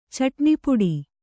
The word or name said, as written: ಚಟ್ಣಿಪುಡಿ